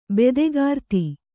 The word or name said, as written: ಬೆದೆಗಾರ್ತಿ